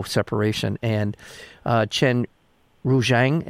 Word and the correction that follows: Chen Ruizhang: am I saying his name correctly? I just want to make sure I'm not butchering it too badly. – Chen Ruizhang. Chen Ruizhang is his name. Yeah. – Chen Ruizhang, thank you.